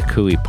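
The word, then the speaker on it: Coie: Perkins, P-E-R-K-I-N-S. You know that part of it. So the Cooey part is a little bit tougher. It's C-O-I-E.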